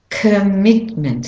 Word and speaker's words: commitment: The O is a schwa sound. Commitment. So the mit is the stressed syllable. I'll say it again. Commitment. Commitment. You can hear that drum beat heavier on the mit.